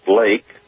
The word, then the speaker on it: Leake: Lake Auctions, which it spells out as L-E-A-K-E. Everybody says, oh, it's Leak Auctions. Well, it's a German name, so it is pronounced Lake.